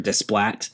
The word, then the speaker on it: Desplat: The sound, both the soundtrack, which I never know how to pronounce this composer's name, but it's Alexandre Desplat. Desplat? Alexandre, yeah. Desplat. – Yeah. That's how I would have said it for sure.